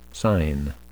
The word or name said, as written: sign